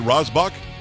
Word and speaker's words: Rasbach: His name is David Rasbach. Did I say that right, David? I think I did. Rasbach? – Yes. Perfect. You said it right.